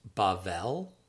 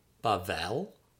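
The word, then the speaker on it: Bavel (בבל): The next word, number three, is Babylon, and that's pronounced Bavel, Bavel.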